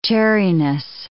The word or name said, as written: chariness